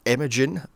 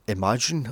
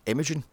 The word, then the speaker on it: Imogen: Starring Anton Yelchin, Tony Collette, Imogen Poots, and it's Imogen, by the way. Not Imagine, or a fucking how some people pronounce a bloody thing, Imogen.